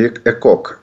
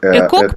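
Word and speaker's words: ECOG: Ну, это ЭКОК. – ЭКОК, правильно, да, читается? – Да, это одна из шкал оценки состояния пациентов.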